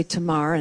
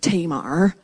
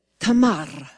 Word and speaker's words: Tamar: And if I was really going to do it properly, it would sound like this, Tamar. I would roll my R because that is the Middle Eastern pronunciation, not Tamar, as one of the other websites called it. It's Tamar. Tamar is listed there, and she is perhaps the most brazen, but also the most branded by the church.